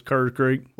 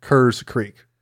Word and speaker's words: Kerrs Creek: That Central Virginia hillbilly might be a little different, but from my end of the mountains, it's Kerrs Creek. – Well, see, that's how I hear it pronounced all the time. – Yeah. – As a northerner. – Is that what it is? – Yeah. As a northerner, it's Kerrs Creek. Like Steve Kerr, yeah.